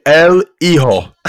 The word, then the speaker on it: El Hijo: Tim, you butchered that Spanish word. Let me do that one for you. El Hijo.